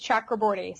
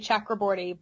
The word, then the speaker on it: Chakraborty: You'd have to check with my in-laws, because now my husband always says Chakraborty, so I say Chakraborty, but in... You know, his family speaks Bengali, and sometimes it's more of a softer sound, like Chakraborty.